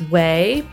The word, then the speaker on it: OUAI: So the first sponsor is Way, pronounced Way but spelled O-U-A-I, and they make skin products.